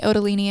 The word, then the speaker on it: Ottolini: If you're inherent, I pronounce it the correct way. – Yeah. – The Italian way, Ottolini, and he pronounces it Ottolini, but I really do it for spelling. – But all the Italians here produce it Ottolini. – Yeah. It's more for spelling, for the sake of like.